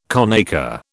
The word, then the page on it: conacre: British English